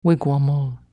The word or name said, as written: wigwamol